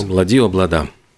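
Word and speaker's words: Ob-La-Di, Ob-La-Da: Она была мисс Египет в 1954 году и в честь родины записала восхитительную песню и «Облади», или как она правильно произносится? – «Облади, облада».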